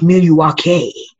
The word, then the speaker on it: Milwaukee: His wee story about Milwaukee, about how it's actually pronounced Milwaukee.